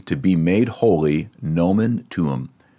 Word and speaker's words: tuum: Notice the vowels don't get blended like you might expect in English. You say both vowels. And since the accent goes on the second to last syllable, almost always in Latin, it's tuum. So the first U is a long one, and the second U is a short one, tuum.